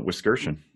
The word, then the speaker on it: Wiskirchen: Wiskirchen. That's a rarity that people get that right. – I was practicing, so... Wiskirchen. It looks like Whisker Chen.